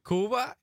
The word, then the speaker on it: Cuba: Because everyone says Cuba, but there it's Cuba, right? – Cuba.